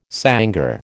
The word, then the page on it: sangar: American English